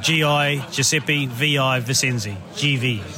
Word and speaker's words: Givi: It's not Givi or any of these other ways. It's GV, right? No, it's GV. So the man behind the company, Giuseppe Visenzi, he was a Grand Prix racer back in the day.